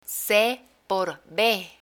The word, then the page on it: ce por be: spanish